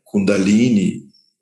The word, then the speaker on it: Kundalini: Kundalini, ou na a pronúncia mais correta, Kundalini, é o Tejas ou seja, um oceano de consciência, bem-aventurança, no sentido de discernimento.